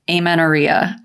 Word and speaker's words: amenorrhea: Moving on to our next question, we are diving into amenorrhea.